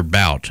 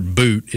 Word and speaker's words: Bout: Now, all day I was saying Victor Bout. Apparently it was the Canadian pronunciation is the correct way to go about that. So Victor Bout is the name of the arms dealer.